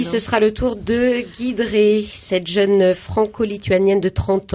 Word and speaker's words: Giedrée: Une soirée humour noir en chanson avec Pierrick et Guidré, on prononce Guidré.